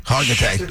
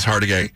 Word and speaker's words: Hargitay: I hope you didn't pronounce her name that way. – Is it Hargitay? – Hargitay. – Oh, no wonder. – Don't call her Hargitay. Not going to get you in the door.